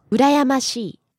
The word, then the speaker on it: 羨ましい: 羨ましい。